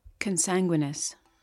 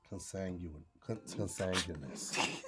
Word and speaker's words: consanguineous: Consanguineous. – Consanguineous. Say it again. – Consanguineous. – Consanguineous. Did I say it right? – Just now you did.